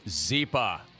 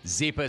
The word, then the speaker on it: Zyppah: Your word is Zipa. Zipa is spelled Z-Y-P-P-A-H. Happy Z spelled backwards.